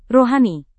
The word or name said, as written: rohani